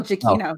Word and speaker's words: Giacchino: I hope I said that right. – You did. – You didn't, but okay. – I did. – No, you did. It's Michael Giacchino. Oh. Yeah, he did say it right.